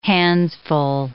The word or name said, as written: handsful